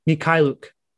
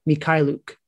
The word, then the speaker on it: Mykhailiuk: I actually said his name wrong earlier. Svi Mykhailiuk. That's what it is. That's how you say Mykhailiuk.